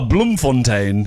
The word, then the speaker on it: Bloemfontein: Oh, Bloemfontein. – Bloemfontein. – Yeah. – Oh, how did I not know that? – How did you not know Bloemfontein? – John Heller? – Educate yourself about Bloemfontein.